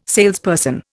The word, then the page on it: salesperson: Indian